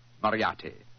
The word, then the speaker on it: Moriarty: I'm afraid I'll have to begin calling it the way it looks. M-O-R-I-A-R-T-Y. Moriarty.